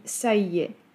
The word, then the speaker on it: ça y est: Pierwsza trudność to wymowa, bo wymawiamy go tak, jakby to były dwa wyrazy, a nie trzy. Ça y est. Zaimek i przykleja się w wymowie do czasownika être.